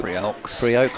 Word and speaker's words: Priaulx: Priaulx, close. The Alex is silent and it rhymes with Priaulx.